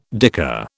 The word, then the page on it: dicker: British English